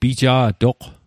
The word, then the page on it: pija'toq: Mi'gmaq